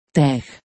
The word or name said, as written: TeX